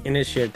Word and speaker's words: Inisherin: Inisherin, thank you.